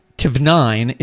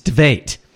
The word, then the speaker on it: Tveit: His name. It's Tveit. – I know. – It's like, it's not Tvnine, it's Tveit.